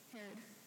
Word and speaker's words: Herod: Herod.